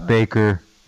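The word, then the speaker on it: Bakker: Really it's Baker. It just says two Baker. K's.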